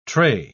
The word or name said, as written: tray